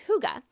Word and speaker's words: hygge: It's spelled H-Y-G-E-E. It looks like hygge or hygiene, but it's actually pronounced hygge.